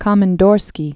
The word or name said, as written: Komandorskiye Islands